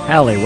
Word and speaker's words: Haley: Haley. One of my missions in life, help pronounce it. Just think of the word valley with an H on it.